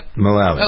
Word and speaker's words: Mullally: Mullally.